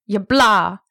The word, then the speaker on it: Yabla: I don't know how to best pronounce it. Yabla. It's kind of like Kablam.